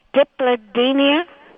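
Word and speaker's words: Dipladenia: It's called, I don't know if I'm pronouncing this right, Diplodenia. – Yep, you did awesome on that.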